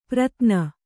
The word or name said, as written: ಪ್ರತ್ನ